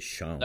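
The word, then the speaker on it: Xun: It's X-U-N, and then I'm going, well, how do you say it? And it's like, okay. Mm. So I wrote Shun, but it's really Shun.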